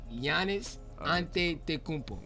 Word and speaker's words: Giannis Antetokounmpo: Yes, Giannis Antetokounmpo.